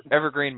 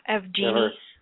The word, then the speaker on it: Evgeny: Evergreen Malkin. – Evgeny.